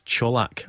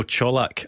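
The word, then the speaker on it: Čolak: Apparently it's Colak, Antonio Colak, and not Colak. So glad we can get that one sorted before the start of the season. – I bow to his greater Croatian pronunciation.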